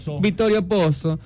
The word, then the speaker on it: Vittorio Pozzo: Vittorio Pozzo.